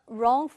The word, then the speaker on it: wrong: 자, 그랬더니 남자가 Oh, you are on the wrong floor. 라고 얘기를 합니다. 자, 우리 귀에는 wrong이라고 들렸는데, 실제 쓸 때는 앞에 W가 있는 요 단어였습니다. 틀린, 잘못된이라는 형용사인 거 아시죠? 묵음이라 발음나지 않고 wrong.